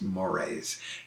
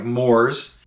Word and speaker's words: mores: But that should never be confused with M-O-R-A-Y, which is a kind of eel. – Right, yeah. – M-O-R-E-S is mores. It looks like mores, uh-huh. – Like it might be related to s'mores, but it's pronounced mores.